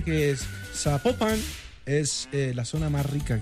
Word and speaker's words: Zapopan: Es Zapopan.